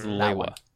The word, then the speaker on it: Lewa: I pronounce the green and lime green colored Toa of Air as Lewa.